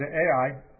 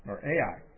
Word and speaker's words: Ai: Verse 2, now Joshua sent men from Jericho to Ai, probably pronounced I, but I'm in the habit of saying Ai, so that's how I'm going to pronounce it,